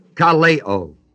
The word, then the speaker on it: kaleo: It is epi kaleo, K-A-L-E long O, meaning to call upon.